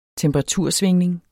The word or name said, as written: temperatursvingning